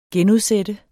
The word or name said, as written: genudsætte